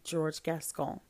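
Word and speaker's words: George Gascón: George Gascon.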